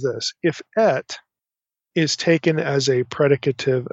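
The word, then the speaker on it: et (את): It's pronounced et, so it's aleph tav, et.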